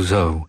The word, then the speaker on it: Zoe: Wspólnie z kolegą z Radia z Kalisza nie wiedzieliśmy dokładnie, jak wymawia się jej nazwisko, ale potem podczas koncertu bardzo ładnie i elegancko nam to wytłumaczyła, że po prostu Zoe.